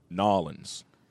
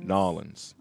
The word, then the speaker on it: New Orleans: If you say New Orleans, you got to say it right when you're down in Nolens. Nolens. That's how they say it down there.